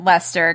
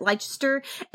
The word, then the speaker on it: Leicester: It's spelled L-E-I-C-E-S-T-E-R. So that could be pronounced a couple of different ways. – I pronounced it Leicester because I'm pretty sure that's the same way as it's spelled in like Leicester Square in London.